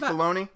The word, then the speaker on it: Filoni: Oh, Dave Filoni? – Yes! I kept thinking something that rhymes with pony.